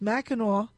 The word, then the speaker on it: Mackinac: And so the hotel is on an island called Mackinac, even though it's spelled Mackinac. It's pronounced Mackinac Island.